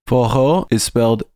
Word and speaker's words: forró: Up in the northeast, there's a whole tradition called forró. – Forró is spelled F-O-R-R-O. And it's actually a Brazilian pronunciation of the English words for all, for its ability to cross race and class lines.